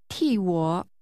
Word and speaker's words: 替我: 替我 The first part of that is what means on behalf of. It's pronounced with a falling tone.